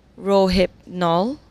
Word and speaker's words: Rohypnol: He said, "I do hope you will correct it is pronounced Rohypnol." – What? Rohypnol. Yes, the drug, roofies. – Ah, it's Rohypnol.